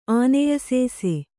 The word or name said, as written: ಆನೆಯಸೇಸೆ